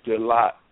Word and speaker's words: Dlott: DeLott. It's spelled DLOTT.